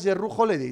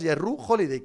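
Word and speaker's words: Jrue Holiday: Me gusta cuando pronuncias Yerru Holiday. ¿Te importaría volver a hacerlo? Gracias, Yerru Holiday.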